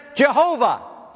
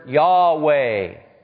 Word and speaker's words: Yahweh: How many of you have ever said Jehovah? Well, actually, that's mispronunciation of God's name. The word Yahweh is simply the verb of being in Hebrew.